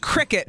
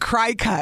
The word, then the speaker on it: Cricket: Yes, and so it's pronounced cricket, not cry-cut.